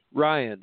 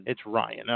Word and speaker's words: Ryan: Is it Ryan Healy or Rion Hidley? – Ryan. – It's Ryan. Okay.